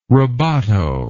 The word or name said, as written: rabato